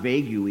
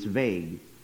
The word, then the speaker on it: vague: And, and, and let me just give you a little grammatical rule that'll help you, because whenever you see an English word that ends in ue, the UE is silent. So it's not vague UE, it's vague.